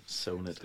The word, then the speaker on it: ZONET: Og øh, det er nemlig gruppen, eller bandet, Sonet. Er det rigtigt udtalt? Yes. – Sonet.